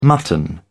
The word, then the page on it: mutton: British English